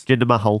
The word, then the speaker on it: Jinder Mahal: Can you say his name properly, please? – Jinder Mahal. – Thank you.